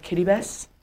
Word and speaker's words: Kiribati: My husband served his mission on this tiny island in the middle of nowhere called Kiribati, or it's spelled like Kiribati.